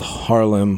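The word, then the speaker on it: Haarlem: This was in World War II, around 1940s, in a town called Harlem, Holland. I think it's the same pronunciation as Harlem. It has like H-A-R-L-E-E-M.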